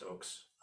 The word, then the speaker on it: Ochs: So it looks like Ox, but it's pronounced Oaks.